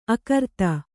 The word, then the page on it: ಆಘ್ರಾತ: āghrāta